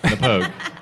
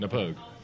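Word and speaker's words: Knappogue: Napoag. – What? – Napoag. – Napoag. – Napoag. Napoag. So I just got to drag the O out a little more.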